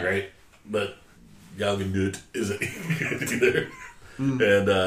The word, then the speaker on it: Gal Gadot: But it did have Gal Gadot in it. She is, bless you, super hot. That's how you say it. I watched videos.